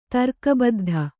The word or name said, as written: ತರ್ಕಬದ್ಧ